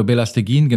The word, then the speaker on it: Belasteguin: Und Fernando Belas, Belastiguin. Genau, Fernando Belasteguin, genannt Bela.